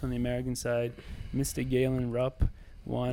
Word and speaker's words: Galen: You mean Gallen? – Gallen. – Gallen. – Gallen Rupp won. – Carl's can't get past calling him Gallen because of the way it's spelled. – It's G-A-L-E-N. – Gallen. – Gallen. – Yeah. How would you spell Galen? – G-A-Y? – Y-L-Galen.